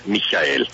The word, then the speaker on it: Michael: Él se llama, tenemos una duda, señor embajador, usted de pronto nos ayuda a precisar si su nombre se pronuncia Michael Michel Michael Bock. Gracias por acompañarnos, muy buenos días. – Muy buenos días. La última opción sería la mejor, Michael. – Michael.